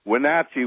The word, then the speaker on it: Wenatchee: Yeah, Denny in Wenatchee, Washington.